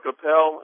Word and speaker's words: Appel: Is it Appel? Did I pronounce it? – It's Mark Appel.